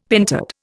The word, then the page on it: pentad: Indian